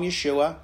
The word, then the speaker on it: Yeshua: And you can call him Yeshua, because that's actually how you say his name in his actual language.